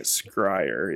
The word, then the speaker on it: Scryer: It's Crier with a S at the beginning. – Yeah, I was just pronouncing it Scryer.